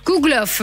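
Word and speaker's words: Kugelhopf: Comment prononce-t-on ça encore, dites-moi? – Alors, c'est le Kugelhof. – Kugelhof, voilà, ce sera plus facile.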